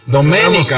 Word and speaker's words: Domenica: Domenica, Domenica, con acento en la E.